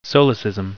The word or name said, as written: solecism